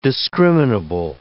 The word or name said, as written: discriminable